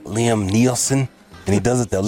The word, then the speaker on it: Liam Neeson: Am I saying his name right? – Yeah, Liam Neeson.